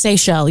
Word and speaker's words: Seychelles: Is it pronounced Seychelles? – Seychelles, yeah. – The Seychelles, islands. – S-E-Y-C-H-E-L-L-E.